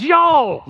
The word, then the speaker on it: y'all: The original Oklahoma Greek is y'all. That's how it's pronounced.